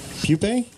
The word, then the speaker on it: pupa: Pupae? – Oh, pupae, pupa.